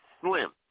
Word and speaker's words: Sljm: All right, first song up on deck, all right, artist goes by the name, I think you pronounce it Slim. He spells it S-L-J-M, all right, instead of I-M, but I guess he pronounced it Slim.